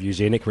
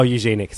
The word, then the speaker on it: Eugenic: I like that you pronounce it like Eugene. – Through Boom Studios. Yeah, oh, Eugenic, sorry. Wait, Eugenic? Eugenic. – I would say Eugenic, but... – Yeah, it's definitely Eugenic.